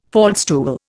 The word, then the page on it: faldstool: Indian